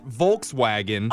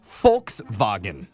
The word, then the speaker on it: Volkswagen: By the way, it's also not Volkswagen. – Oh, no. – What? – This is my car. – It's Volkswagen. – No, not Volks, because the V is pronounced with an F. So it's Volkswagen.